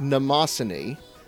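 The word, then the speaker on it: Mnemosyne: Rin from Daughters of Memnocine, so of course I had to go with the ever-classy Colleen Clegg. – First of all, it's pronounced Namasini.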